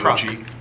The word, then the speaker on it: CRCOG: So I reached out to, I never, how do you pronounce that? C-R-C-O-G? – Croc. – It's Croc? – Croc? Yeah, Croc, yeah.